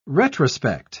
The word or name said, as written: retrospect